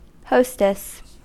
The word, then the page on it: hostess: American English